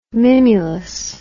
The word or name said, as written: mimulus